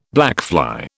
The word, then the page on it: blackfly: British English